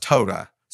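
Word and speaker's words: Toda (תודה): The last word I used last week was Tuda. Actually, it's pronounced Toda.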